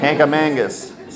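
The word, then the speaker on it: Kancamagus: It's not how you say that. How do you say it? – Kankamangus. – So you know a Flatlander by how they pronounce that one word.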